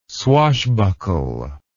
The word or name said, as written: swashbuckle